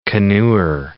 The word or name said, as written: canoer